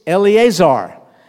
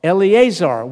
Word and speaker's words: Eleazar: Lazarus's name is Lazarus in Greek, but in Hebrew, it's Eleazar. Eleazar, which means God is my help.